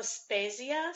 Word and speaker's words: La Spezia: Portofino, La Spezia.